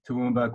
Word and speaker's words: Toowoomba: He still maintains a busy herbal and naturopathic practice in Toowoomba. Did I pronounce that correctly? – Yeah, that's good. – Toowoomba, Queensland, where he has been in continuous service for 34 years.